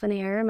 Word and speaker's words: Lenier: I'm on Instagram and TikTok at Sage Lanier. My last name is spelled L-E-N-I-E-R.